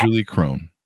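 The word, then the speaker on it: Krone: Julie Crone. Did I get those names right? – You did.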